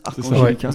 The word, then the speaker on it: Arcangelica: Le premier morceau, c'est Arcangelica, c'est comme ça qu'on prononce Arcangelica. – C'est ça, ouais, exactement.